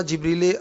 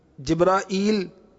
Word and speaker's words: جبریل: ان کے جو ادائیگی ہے اس کے تین تلفظ ہیں۔ کفریہ کلمات کے بارے میں سوال جواب کے صفحہ نمبر 312 پر امیر اہل سنت نے لکھا ہے، جبریل کہتے ہیں یہ بھی ٹھیک ہے، جبرائیل یہ بھی